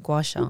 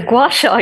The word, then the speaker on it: Gua Sha: Am I saying it right? Gua Sha. – Yeah, you are saying it right. So Gua Sha, yeah, you're absolutely right.